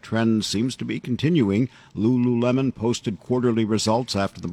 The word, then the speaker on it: Lululemon: And Lululemon's quarterly results. Did I say that right, Lululemon? – Lululemon, yeah.